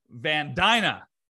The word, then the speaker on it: Van Dyna: Today, we are joined by a special guest, Jordan Van Dyna. Rhymes with vagina. Which with vagina. You nailed it.